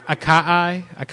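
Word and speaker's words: açaí: The acai. – They all have acai in them. – How do you say it? Acai? – Acai. – Acai. Okay. Yeah, one of those.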